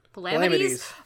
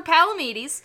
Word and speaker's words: Palamedes: Yes, he is a real one, and I'm pretty sure it's more accurately pronounced Palamedes. But they're calling him Sir Palamedes.